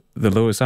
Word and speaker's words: Louis: You were right the first time when you said Louis. It's a common mistake to pronounce L-O-U-I-S as L-E-W-I-S. L-O-U-I-S is pronounced Louis, as in Robert Louis Stevenson, Louis the 14th, etc.